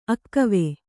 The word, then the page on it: ಅಗುವು: aguvu